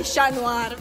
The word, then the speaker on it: Chat Noir: La pronuncia in francese in realtà è Chat Noir.